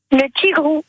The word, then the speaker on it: tigreau: Le tigre. – Et oui, le tigre, ça s'écrit T-I-G-R-E-A-U.